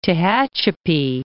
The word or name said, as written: Tehachapi Mountains